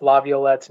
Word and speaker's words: Laviolette: because I can't pronounce Laviolette's name, you just did.